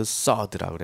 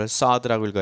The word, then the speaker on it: sword: 그래서 아더왕의 칼 같은 거 할 때도 sword라 그러는 거지. 이걸 sword라고 읽어야지 sword라고 읽으면 안 돼. 우리가 answer 그러면 이거를 answer라고 읽지 이거를 answer라고 안 읽잖아. 왜? W는 묵음이잖아.